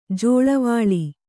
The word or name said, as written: ಜಲ್ಲೆವಲೆ